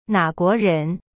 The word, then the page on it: 哪国人: na guo ren